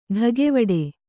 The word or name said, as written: ಢಗೆವಡೆ